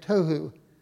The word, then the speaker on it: tohu: The Hebrew word is tovu, tohu. It's how you say it, tohu.